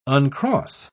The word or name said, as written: uncross